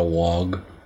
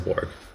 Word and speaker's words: warg: It's not a wog. – No, a polywog? – No, Wog from Game of Thrones. – Oh, that is a warg. That's just people pronouncing it with a northern accent.